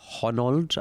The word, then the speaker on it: Honnold: It basically follows Alex Honnold.